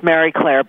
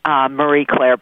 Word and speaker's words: Marie Claire: It's actually pronounced Mary Claire, but in the U.S. we call it Marie Claire. So if I say Mary Claire, that's just out of habit.